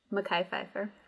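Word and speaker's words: Mekhi Phifer: Mekhi Pfeiffer.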